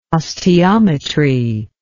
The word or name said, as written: osteometry